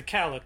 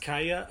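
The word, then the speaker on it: Calla: So, fifth book, The Wolves of the Kala, comes out 2003. Could, some people say Kala, some people say Kaya, you know. Personally, in the book, they actually say it's supposed to be pronounced Kaya, I think, but it's, yeah, people call it The Wolves of Kala.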